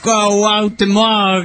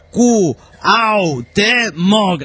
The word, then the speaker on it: Cuauhtémoc: Llega el austral radicado en Canadá, mexicano, el hombre chilango en las venas, dice, a ver pirata, pronuncia conmigo, sí, guatanejo, cuauhtémoc, y tilsalclip, anticutli, dice, ahora, a ver, sí, guatanejo, cu, au, te, moc, la otra que se vaya a la burger, dice.